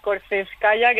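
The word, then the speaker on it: Korzhenevskaya: Sí, y el Korseskaya, que si no sé si sabría pronunciarlo bien. – No, lo has pronunciado muy bien.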